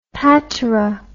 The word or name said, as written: patera